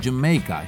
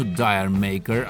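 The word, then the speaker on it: D'yer Mak'er: În pronunția britanică, numele piesei se citește Jamaica și nu Dire Maker, așa cum am putea crede.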